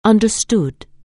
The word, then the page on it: understand: anglais